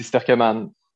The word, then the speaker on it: Sterckeman: C'est Sterkeman.